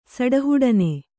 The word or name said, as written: ಸಡಹುಡನೆ